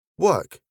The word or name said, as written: work